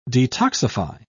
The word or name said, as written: detoxify